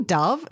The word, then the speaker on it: Dov: Is his name Dove? D-O-V, but they were pronouncing it Dove.